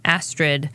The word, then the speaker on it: Astrid: A-S-T-R-I-D. – Astrid. Yes. Not asterisk, but Astrid. It's like an asteroid and an asterisk that are mixed together.